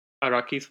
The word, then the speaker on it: Araki: Do you think it's Araki's fault? Is that how you say it? A-R-A-K-I? – Araki, yeah.